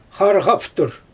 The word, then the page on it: xarxaftur: Xyzyl